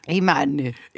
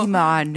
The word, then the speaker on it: Iman: Es que me hacía risa que lo pronuncié como... – Iman. – No tiene ninguna doble N, ni E, ni nada. – No. – Iman. Como una buena que piensa que está hablando en francés.